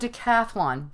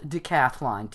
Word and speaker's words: decathlon: The word decathlon, D-E, it's spelled decathlon, D-E-C-A-T-H-L-O-N. But I don't know about you guys, but I've always said decathlon, pentathlon, heptathlon. You want to throw in that sound in there, but it's not.